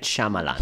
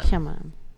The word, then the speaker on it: Shyamalan: Night Shyamalan. Shyamalan.